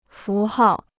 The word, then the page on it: 符号: fú hào